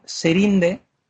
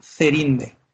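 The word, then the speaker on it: Írinde: Y cuando le llamaban Serinde, que es como la llamarían la mayoría, decía que no, mira, vosotros pronunciad como queráis, pero a mí llamadme Cerinde, con la Z, que es mi nombre.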